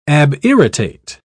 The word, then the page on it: abirritate: British English